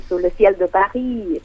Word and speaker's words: Sous le ciel de Paris: Con Edith Piaf y Sous le ciel de Paris. ¿Puede ser? Algo así era, pero bajo el cielo de París. – Sí, Sous le ciel de Paris.